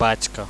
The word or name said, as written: backa